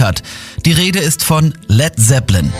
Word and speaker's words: Led Zeppelin: Led Zeppelin, das A in Led ließ er einfach weg, damit es nicht wie Blei ausgesprochen wird.